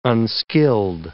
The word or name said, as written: unskilled